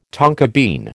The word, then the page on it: tonka bean: American English